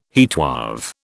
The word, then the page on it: heatwave: British English